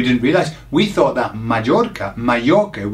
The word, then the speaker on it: Mallorca: Remember the R. Pronounce the R. Mallorca.